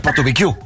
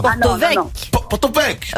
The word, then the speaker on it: Porto-Vecchio: On doit pas dire Porto-Vecchio. – Non, non, surtout pas. – Porto-Vec.